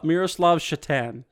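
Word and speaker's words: Šatan: Miroslav Satan. – Miroslav Shatan. Satan or Shatan? I always did the S like sh.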